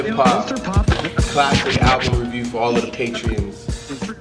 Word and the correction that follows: patrons: Did I say it right? – Patrons. No, patrons.